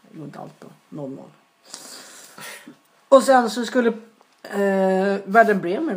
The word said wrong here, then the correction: Werder Bremen: vinna mot... – Werder Bremer. – Alltså jag kan inte uttala... – Werder Bremen. – Ja, Bremen tror jag.